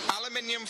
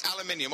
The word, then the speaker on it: aluminium: So, it's aluminium, or however you say it. How do you say it? – Aluminium. – Aluminium. There we go.